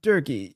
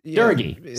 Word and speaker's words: Durgy: It's Durgy, right? – Durgy is Durgy. Sorry, hard G.